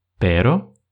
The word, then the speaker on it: pero: Есть очень похожие два слова – pero и perro. Казалось бы, мы всего лишь усилили немного произношение буквы R, однако смысл полностью поменялся. Если pero это слово означает но, то тогда perro означает собака.